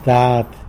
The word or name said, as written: dad